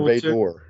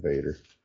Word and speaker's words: Vader: Well, Vader or Vador. Yeah, I don't think we ever heard anyone say Vador. It's always Vader.